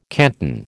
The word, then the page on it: canton: American English